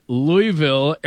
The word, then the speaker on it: Louisville: Yeah, the S is silent. It's Louisville.